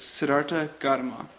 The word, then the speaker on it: Siddhartha Gautama: I looked up the pronunciation and then couldn't read my writing very well there or figure out how to say it. But it's Siddhartha Gautama. That's his name.